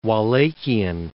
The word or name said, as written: Wallachian